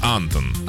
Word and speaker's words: Антон: На А ударение. Антон.